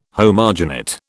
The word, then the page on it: homogenate: British English